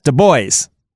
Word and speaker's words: Du Bois: Du Bois? – Sure, why not? – Du Bois. – There you go. – D-U capital B-O-I-S. Du Bois.